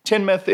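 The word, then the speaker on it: Tynemouth: They pronounce the mouth is Muth. Tynmouth in northeast England in 1944.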